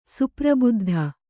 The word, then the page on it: ಸುಪ್ರಬುದ್ಧ: suprabuddha